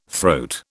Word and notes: throat: British English

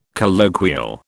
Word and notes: colloquial: British English

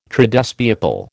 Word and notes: Tradespeople: American English

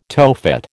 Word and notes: Tophet: American English

